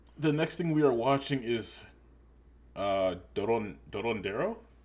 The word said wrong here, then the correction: Dorohedoro: Oh, Dorohedoro, the anime, right? – Yes.